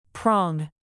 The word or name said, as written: prong